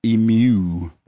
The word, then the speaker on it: emu: Emu. – I'm emu, sorry. That's how you say it? – Emu, yeah, like Mew from Pokemon on E.